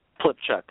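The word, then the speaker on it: Pylypchuk: Hill Plitchuk. Is that how you say his name? – Plitchuk. – John's name, Pilchuk, yeah, he's a great guy.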